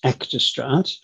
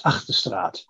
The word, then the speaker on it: Achterstraat: The most common one is Achterstraat. When I grew up, couldn't speak English at the age of four, and we pronounced it as Achterstraat. But when I learned English at 4 years of age, it sort of changed to Achterstraat.